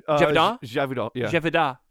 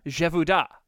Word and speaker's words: Gévaudan: The G is a J and the N doesn't exist. – Gévaudan? – Gévaudan. – Gévaudan.